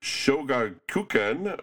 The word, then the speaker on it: Shogakukan: Simultaneously, the Japanese publisher Shogakukan opened their U.S.